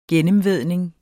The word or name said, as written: gennemvædning